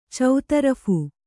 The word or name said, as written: ಚೌತರಫು